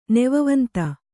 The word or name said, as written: ನೆವವಂತ